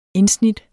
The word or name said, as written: indsnit